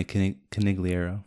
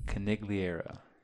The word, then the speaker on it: Conigliaro: Tony Conigliaro. – Conigliaro.